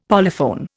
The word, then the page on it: polyphone: Indian